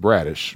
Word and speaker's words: Bradecich: It's spelled B-R-A-D-E-C-I-C-H, but it's pronounced Bradish.